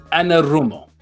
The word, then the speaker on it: Anarumo: Most importantly, we have to discuss the hiring that happened today, and that is of the defensive coordinator, Lou Anarumo.